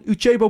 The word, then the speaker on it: Uchebo: En eh, om dat te doen hebben we in de uitzending aan de telefoon van de Teen van Uchebo podcast. Zeg ik dat goed? Ja, dat zeg je helemaal goed.